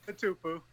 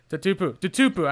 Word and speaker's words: Tatupu: We've got Lofa Tatapu. Is that how you pronounce it, at least for your last name, Lofa? – Close enough. – Tatupu. – It's close though. – Tatupu.